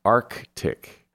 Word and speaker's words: Arctic: Same with Arctic. It should be pronounced Arctic.